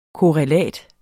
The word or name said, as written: korrelat